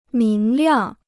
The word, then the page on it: 明亮: míng liàng